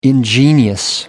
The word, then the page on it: ingenious: American English